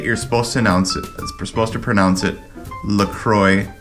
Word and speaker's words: La Croix: She kept pronouncing it La Croix. And I kept correcting her, of course, after listening to you pronounce it with the French accent for years. I kept telling her that say it is La Croix, and they are obsessed with it, so they must be right. Well, she looked it up and surprised me with the knowledge that it rhymes with enjoy.